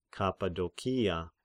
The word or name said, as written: Kappadokia